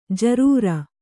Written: ಜರೂರಿ

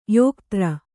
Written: ಯೋಕ್ತ್ರ

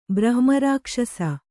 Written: ಬ್ರಹ್ಮರಾಕ್ಷಸ

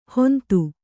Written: ಹೊಂತು

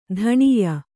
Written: ಧನಾಯೆ

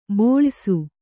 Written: ಬೋಳಯಿಸು

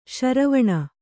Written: ಶರವಣ

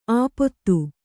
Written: ಆಪೊತ್ತು

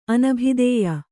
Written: ಅನಭಿದೇಯ

